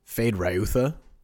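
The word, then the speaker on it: Feyd-Rautha: Fade Rayutha.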